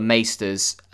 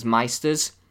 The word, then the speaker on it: maester: oh, by the way, in the first season, just a little thing, you'll hear maesters referred to as meisters. It is maester, and they do settle on that pronunciation eventually, but it's a bit up and down for the first season or so.